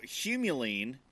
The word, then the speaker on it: humulene: So humulene is an essential oil I pronounced that right. Right? – Yeah.